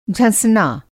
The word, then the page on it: झँसना: Hindi